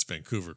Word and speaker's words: Vancouver: Now, did I pronounce Vancouver correctly? Right? Is that yes, correct? You did.